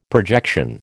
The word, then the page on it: projection: American English